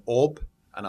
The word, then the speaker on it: Oban: So we pronounce it Oban. It's spelled O-B-A-N, but it's Oban. – Yeah, like as if the B and the N run into each other.